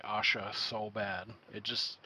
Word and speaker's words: Asha: So yeah, we're talking about, I heard a bunch of people say it, and I'm saying it Asha. – That's how I heard it as well. Okay. I saw like a pronunciation thing. – Yeah. – And then I also watched a little 20-minute video that the FBI put out, and they said Asha as well.